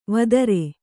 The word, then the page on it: ವೇತ್ರಿ: Kannada